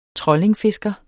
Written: trollingfisker

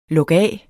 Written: lukaf

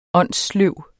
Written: åndssløv

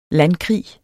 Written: landkrig

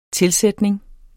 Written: tilsætning

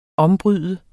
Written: ombryde

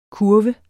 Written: kurve

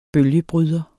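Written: bølgebryder

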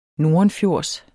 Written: nordenfjords

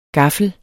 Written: gaffel

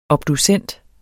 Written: obducent

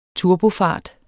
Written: turbofart

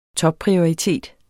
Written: TOPPRIORITET

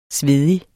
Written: svedig

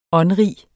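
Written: åndrige

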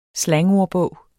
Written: slangordbog

